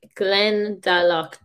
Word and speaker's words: Glendalough: because obviously that's the thing about Irish names and name places. It is bloody difficult spelling, and it's pronounced in a completely different way than it is spelled. So that is true. So Glendalough is Glendalough, G L E N D A L O U G H.